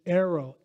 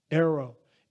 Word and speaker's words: airo: The Greek word in verse 36 is aero, A-I-R-O, long O, aero.